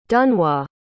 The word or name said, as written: dunois